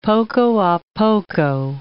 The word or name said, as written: poco a poco